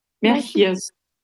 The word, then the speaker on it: Merchiers: Ja, und der Name wird dann ausgesprochen? – Merchiers. Ja, wie Sie wollen.